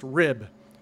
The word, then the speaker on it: RHIB: It was a vessel of the class RHIB, pronounced RIB, rigid hull inflatable boat, what civilians might call a rubber boat.